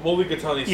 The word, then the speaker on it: Mulligatawny: M-U-L-L-I-G-A-T-A-T-W-N-Y. – Hold on. M-U-L-L. – I-G-A-T. – Oh, Mulligatawny soup.